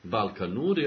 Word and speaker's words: Balkan Nord: Balkanur, ja... – Tako se izgovara. Ajde po vuku. – Balkan Nord. – Se piše.